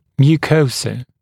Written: mucosa

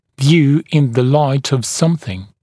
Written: view in the light of smth

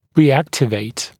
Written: reactivate

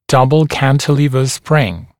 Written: double cantilever spring

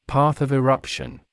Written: path of eruption

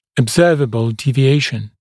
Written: observable deviation

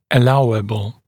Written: allowable

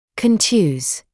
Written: contuse

